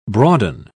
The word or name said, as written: broaden